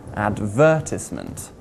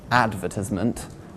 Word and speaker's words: advertisement: The word advertisement has four syllables, and we place the stress on syllable number two. Advertisement, not on syllable number one, advertisement, or syllable number three, advertisement.